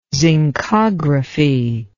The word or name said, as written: zincography